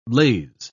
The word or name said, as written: lase